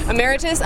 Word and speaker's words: emeritus: She's Professor Emeritus at, I'm never sure if I'm pronouncing that word correctly. – Emeritus? – Emeritus, thank you.